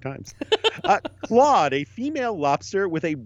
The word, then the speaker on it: Clawde: So Claude is spelled C-L-A-W-D-E. – Claude. – And it's Claude.